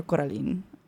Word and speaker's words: Coraline: Or I think it's Coraline or Coraline. I don't really, because in Italian, you pronounce the E like Coraline, but I don't know, Coraline, either one, by Maneskin.